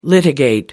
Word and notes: litigate: American English

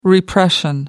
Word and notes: repression: American English

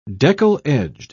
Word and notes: deckle-edged: British English

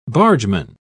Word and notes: bargeman: British English

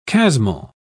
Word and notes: chasmal: British English

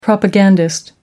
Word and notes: propagandist: American English

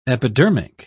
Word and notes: epidermic: British English

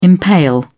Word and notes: impale: British English

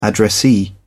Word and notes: addressee: British English

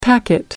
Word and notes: packet: American English

